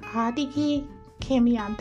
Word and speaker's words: Hideki Kamiya: Hideki Kamiya, that's his name.